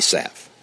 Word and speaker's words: salve: The American pronunciation is salve without the L. And so since that's the American pronunciation, that's the right pronunciation.